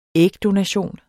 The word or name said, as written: ægdonation